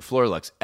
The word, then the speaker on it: Floralux: I don't even know how to say this word, Floralux. F L O R A L U X.